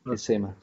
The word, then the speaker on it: CEMA: no sé si se pronuncia de esa forma. Está bien, sí, CEMA, sí.